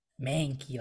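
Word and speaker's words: Mahnke: With art by Doug Mankey, or Doug Monk, however you say his name. – Mankey, like the Pokemon.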